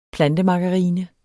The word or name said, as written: plantemargarine